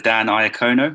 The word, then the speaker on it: Dan Iacono: It is Mr. Dan Iacono.